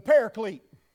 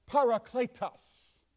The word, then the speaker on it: parakletos: It is the word, now if you're Southern and you're from the United States, you would say the paraclete. But that's not how the Greek would pronounce it. They would call it parakletos. That would be the Greek pronunciation.